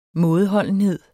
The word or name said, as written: mådeholdenhed